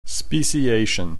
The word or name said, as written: speciation